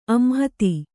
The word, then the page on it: ಆಂಹತಿ: āmhati; Kannada